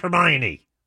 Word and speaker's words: Hermione: And it's pronounced Hermione.